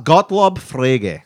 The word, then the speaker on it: Gottlob Frege: Gottlob Frege. That's how you pronounce it. It's German as fuck.